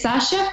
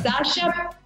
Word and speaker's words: Sascha: Instagram, Twitter, Facebook y YouTube es Sasha Fitness. Mi nombre se escribe S-A-S-C-H-A. Snapchat es el único distinto porque me robaron el usuario y es Sasha guión bajo fitness.